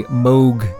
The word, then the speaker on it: Moog: I pronounce it Moog because Wendy Carlos pronounces it Moog. Up until recently, I've been pronouncing it Moog. So it depends on my mood. It depends on my Moog, how I pronounce it.